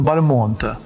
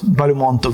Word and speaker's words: Бальмонт: Это певец, тенор встретил у него Легран Константин Бальмонтин, великого Константина Бальмонта. В другом месте Прокофьев пишет, что именно от самого Бальмонта он узнал, что фамилию нужно произносить с ударением на последний слог по-французски.